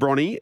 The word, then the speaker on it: Bronte: Because you're both from Bronte, Can you say it correctly? – Bronny. John from Bronny and Shane from Bronny.